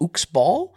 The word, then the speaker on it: Uxbal: I believe it's pronounced Uxbal. Uh, it's U X B A L.